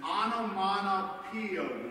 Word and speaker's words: onomatopoeia: It's a word that is an onomatopoeia word.